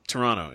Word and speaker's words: Toronto: Isn't it pronounced Toronto? – Toronto, yeah. No T. You just gotta blur through it.